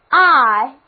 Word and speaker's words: eye: 我们知道这个词根本身也是一个单词 eye 字母组合 ey 发 i 的音，这个读音仅见于 eye 及其派生词。